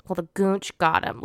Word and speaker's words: goonch: It's also called a goonch. Goonch? Okay, I feel like that's how you say it. It's G-O-O-N-C-H. Tell me that's not goonch.